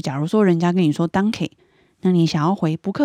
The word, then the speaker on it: Danke: 他们两个差别在哪里？他们差别在尾音。像我刚刚说的 “danke” 是奥地利的发音，那像德国的发音呢，我们发 “danke”。所以在尾音上面会有一点不一样。